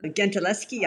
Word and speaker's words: Gentileschi: is that how you say it? – Gentileschi, I think. Yeah, I guess so.